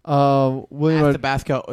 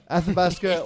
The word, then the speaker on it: Athabasca: Yeah, I feel like I'm not pronouncing it right. Anyway. – Athabasca Oil Corporation. – What? All right, Athabasca.